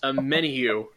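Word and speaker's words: Omenihu: and I have a terrible time trying to pronounce his last name. It's Charles Amenihu is how it's said.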